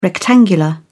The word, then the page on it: rectangular: British English